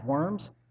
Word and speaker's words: Worms: Now on your sheet, it looks like Diet of Worms, which sounds like something a second grader would force a friend to do.